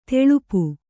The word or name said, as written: ಥಳಪು